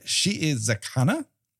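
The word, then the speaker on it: Shiizakana: She is Zakana. There's two eyes there, and so each should be pronounced as its own section.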